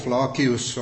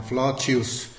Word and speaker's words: Flacius: Här har jag haft en del bryderi med de här uttalen. Det har ju varierat genom tiden. Så att det klassiska latinet skulle säga K. Flacius. Och i Tyskland säger man Flacius. Här på svenskt område säger man vanligtvis Flasius.